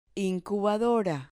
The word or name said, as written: incubadora